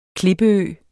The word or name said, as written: klippeø